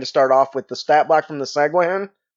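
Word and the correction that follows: sahuagin: Sahagin. – How about you, Alex? – Sahagin, that's how I know it.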